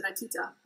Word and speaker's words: Rakita: Am I pronouncing that right? Rakita? – Yes, their local company is Rakita.